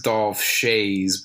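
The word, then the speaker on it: Dolph Schayes: Dolph Shays? Dolph Shuez? I don't know. Dolph. – Oh, that's Dolph Shays, baby.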